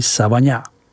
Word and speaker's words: Savagnin: vyslovuj Savagnin.